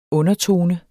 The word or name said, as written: undertone